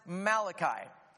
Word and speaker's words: Malachi: Now, forget the whole Italian prophet thing, because all of the prophets are Jewish Israelites, and it's pronounced Malachi.